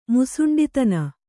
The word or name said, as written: ಮುಸುಂಡಿತನ